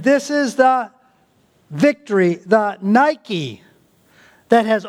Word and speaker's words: Nike: The word victory is translated in the New Testament as victory or overcome is the Greek word Nike. It's pronounced a little different than the Greek, but Nike.